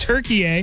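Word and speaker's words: Türkiye: It's crazy that we pronounced it Turkey for the longest time, but it's Turkey A.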